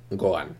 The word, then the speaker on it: Gohan: Entonces, pues Gohan sería Gohan, ¿no? Vamos a decir, porque la H es muda, no se pronuncia.